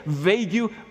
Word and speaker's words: vague: And the Chinaman went on, some of the words are so vague that I don't understand its meaning. What do you say? Vague, V-A-G-U. No, again, my friend, you don't pronounce the last two letters. It is just so vague.